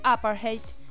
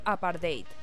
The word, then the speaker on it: apartheid: Por eso es que escuchan que Daniel pronuncia apartheid y yo pronuncio apartheid. Las dos formas son válidas.